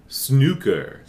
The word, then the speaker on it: snooker: And of course, if the vowel sound in snooker rhymes with look or book, then that's totally correct. But in America, those things don't actually rhyme.